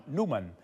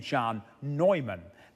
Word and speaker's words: Neumann: Newman, or as his name is sometimes pronounced, St. John Neumann.